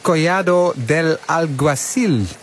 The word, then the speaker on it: Collado del Alguacil: Het is een loodzware bergetappe van bijna 187 kilometer van, moet ik het in mijn beste Spaans zeggen, La Calahorra na Collado del Alguacil. Toch, wielercommentator Andries Lamer? – Ja, toch, als in dat ik eventjes die uitspraak beoordeel. Nou, helemaal goed, helemaal goed.